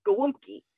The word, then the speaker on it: gołąbki: You know, those galopkis. And I'm like, what the heck is a galopki? Well, in Polish, the food is pronounced gołąbki, but it is spelled galopki.